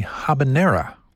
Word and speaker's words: habanero: On occasion, one of those is the habanera. The correct pronunciation does not make use of the Y sound.